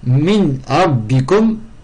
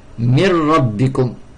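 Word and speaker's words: mir rabbikum (مِنْ رَبِّكُمْ): Min rabbikum, ça se prononce mir rabbikum, mir rabbikum.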